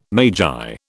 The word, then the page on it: magi: British English